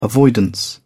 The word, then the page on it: avoidance: British English